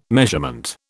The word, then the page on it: measurement: British English